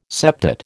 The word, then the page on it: septet: American English